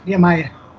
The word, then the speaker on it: Niemeyer: I'm kind of pronouncing it a little bit more in Spanish, but um, uh, maybe you can correct that in the proper Portuguese, right? – It's Niemeyer.